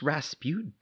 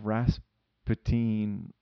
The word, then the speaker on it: Rasputin: It's just Rasputin. Like, oh. – Yeah. – You know, rah-rah Rasputin Yeah,